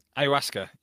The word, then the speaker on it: ayahuasca: But you mentioned, I believe it's, I'm going to butcher it, but ayahuasca. Did I say that right? – Yes, ayahuasca. Yes, correct.